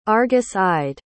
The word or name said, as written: argus-eyed